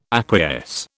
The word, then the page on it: acquiesce: British English